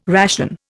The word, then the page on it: ration: Indian